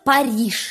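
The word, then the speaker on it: Paris: А я узнала, как называется по-французски город Париж.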